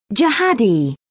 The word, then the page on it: jihadi: British English